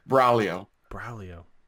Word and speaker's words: Braglio: Braglio. – Okay, that's the proper Italian pronunciation, although no one from where I live or grew up says it right unless it's their name.